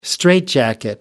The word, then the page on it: straightjacket: American English